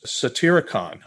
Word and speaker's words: Satyricon: You talk about this work by Petronius, Satyricon.